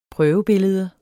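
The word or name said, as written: prøvebillede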